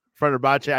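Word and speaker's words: Fenerbahçe: Mourinho is joining Turkish club Hunter Branch. I think how you say it. – Yes. – This is Fenerbahce. Sure. Yeah. Fenerbahce.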